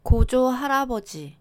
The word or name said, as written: 고조할아버지